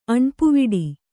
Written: ಅಣ್ಪುವಿಡಿ

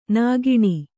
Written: ನಗಣ

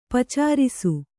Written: ಪಚ್ಚರಿಸು

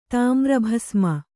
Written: ತಾಮ್ರಭಸ್ಮ

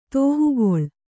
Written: ತೋಹುಗೊಳ್

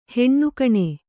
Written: ಹೆಣ್ಣುಕಣೆ

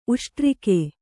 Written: ಉಷ್ಟ್ರಿಕೆ

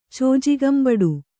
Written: ಚೋಜಿಗಂಬಡು

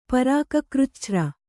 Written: ಪರಾಕಕೃಚ್ಛ್ರ

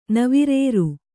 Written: ನವಿರೇರು

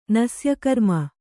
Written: ನಸ್ಯಕರ್ಮ

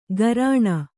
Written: ಗರಾಣ

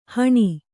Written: ಹಣಿ

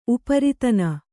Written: ಉಪರಿತನ

